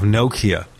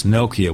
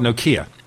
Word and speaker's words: Nokia: So it's Nokia with the emphasis on the first syllable, ladies and gentlemen. I used to call it Nokia, but now I learned it's Nokia. – And well, it's kind of Nokia in the United States. I think in Finland they say Nokia.